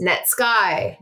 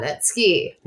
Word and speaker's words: Netsky: His name is pronounced Netsky, not Netsky.